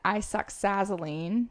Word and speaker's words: isoxazoline: It's an isoxazoline, is that how you say it, product. – Yep.